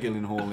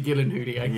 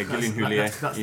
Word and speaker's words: Gyllenhaal: It's got Jake Gyllenhaal in, Rob Downey Jr. It's actually Gyllenhaal. – Yeah, Gyllenhaal is how you actually pronounce it.